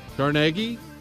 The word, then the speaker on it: Carnegie: Carnegie. That's how you say his name, Chris.